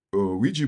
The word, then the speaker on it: Ouija: Ouija board.